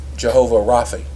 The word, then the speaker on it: Jehovah Rapha: Could you slow down a minute and go back to that one angel, Jehovah Ravi? How did you say it? – Jehovah Rapha.